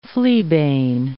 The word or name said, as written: fleabane